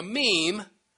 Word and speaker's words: meme: In case you forgot, a meme, which rhymes with gene, as in genetics, has the same propensity to repeat itself and advance in numbers, that is, to proliferate.